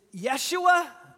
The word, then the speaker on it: Yeshua: How's that pronounced?" I said, "Yeshua Nazareah."